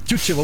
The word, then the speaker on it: Тютчев: Тютчева, вот так произносится, да? А, я думаю, Тютчева. – Тютчев. – Тютчев, окей.